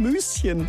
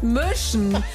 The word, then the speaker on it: Müschen: Vielleicht ja auch in Müschen. – Das heißt Möschen. Genauso wie Segeletz mit langem E oder Bückwitz mit langem Ü.